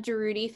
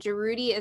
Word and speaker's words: Jaroudi: It's the Jarudi family. So the T-H-E, and then Jarudi is spelled J-A-R-O-U-D-I, and then family.